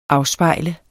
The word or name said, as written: AFSPEJLET